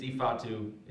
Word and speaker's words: Ifeatu: Yeah, well, that's a nick. It, it's Ifeatu. – It, Ifeatu, yeah.